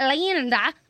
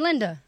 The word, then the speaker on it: Lynda: S-Y-R-U-P. – Yeah. – Syrup. – Syrup. – I'm not Linda. I'm Linda. Like, that's a Y in my name.